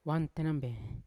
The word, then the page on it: wamtenambe: Yawa